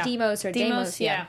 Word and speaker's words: Deimos: Deimos, I think it said it right. – Yeah. Deimos.